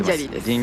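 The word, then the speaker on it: Din Djarin: これイントネーション合ってるディン・ジャリンで合ってます。 – ディン・ジャリンです。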